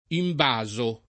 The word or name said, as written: imbasare